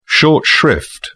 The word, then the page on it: short shrift: British English